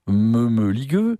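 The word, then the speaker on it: Mămăligă: Et dans Mamaliga, pour le prononcer à la roumaine, Meumeligueux,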